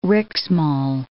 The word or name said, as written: Riksmal